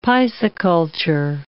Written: pisciculture

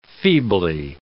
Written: feebly

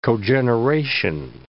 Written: cogeneration

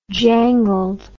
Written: jangled

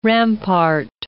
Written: rampart

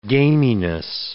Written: gaminess

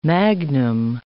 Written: magnum

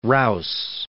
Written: Rous

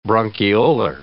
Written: bronchiolar

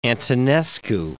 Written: Antonescu

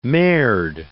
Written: merde

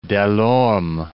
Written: de l'Orme

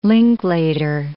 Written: Linklater

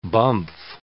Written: bumph

